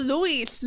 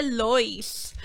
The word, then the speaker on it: Lois: Yo Luis. No lo entendía. Yo tuve que buscarlo. Después de terminar la película, yo, se escribe Lois.